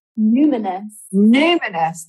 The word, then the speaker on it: Numinous: Numinous, the founder of the Numinous.